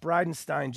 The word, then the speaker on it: Bridenstine: I appreciate the fact that Bridenstine just has S-T-I-N-E and at the end of his name. Now you know how to pronounce that. Because if it's S-T-E-I-N or S-T-I-E-N, nobody knows. It's Steen or Stein. Half the time it's Steen. Half the time it's Stein. Jim's ancestors had the courtesy to just drop one of the letters so it'd be really obvious to us Americans how to pronounce his name.